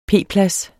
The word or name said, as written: p-plads